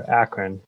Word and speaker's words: Akron: Did I pronounce Akron okay? – Yeah, I'm trying to work on my Midwest accent out here, Akron. Yeah, and if you're from here, it's much more like Akron.